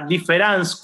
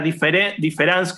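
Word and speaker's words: différance: Y diferencia es una palabra que inventa Derrida eh, que juega justamente con el hecho de que difference en francés se, puede, se pronuncia igual si se escribe con A o con E. Lo que Derrida quiere remarcar es que la difference con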